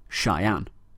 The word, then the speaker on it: Cheyenne: It appears I completely mispronounced the location of Listener Joseph, the capital of Wyoming, which Listener Wes informs me should be pronounced Cheyenne.